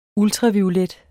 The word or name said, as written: ULTRAVIOLET